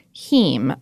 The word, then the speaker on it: heme: But their secret ingredient, and by secret I mean they announce it all over the place, is a compound called heme. I'm saying that correctly. It's spelled H-E-M-E.